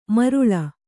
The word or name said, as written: ಮರುಳ